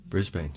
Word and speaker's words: Brisbane: Brisbane? – Brisbane, sure.